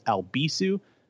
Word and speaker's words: Albisu: And if you want to follow me on social media, do so by following at Alex Albisu. Last name is spelled A-L-B as in boy, I-S as in Sam, U.